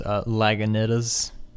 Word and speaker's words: Lagunitas: Lagunitas. Is that how you pronounce it? – No, it's Lagunitas.